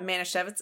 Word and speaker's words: Manischewitz: If you guys don't know what Manischewitz is, it is a kosher wine.